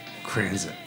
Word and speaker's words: Franzen: Franzen. F. R. A. N. Z. E. N. – Oh, okay. I would like to thank you for pronouncing it correctly.